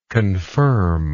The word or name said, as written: confirmingly